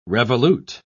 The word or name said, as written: revolute